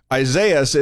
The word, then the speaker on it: Isaias: Isaias is technically pronounced Isaias, which is a Spanish form of the name Isaiah.